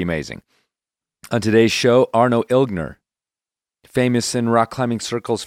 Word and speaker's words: Ilgner: And one thing I found out is that you do pronounce the G in Ilgner. Just slightly. Just hit it.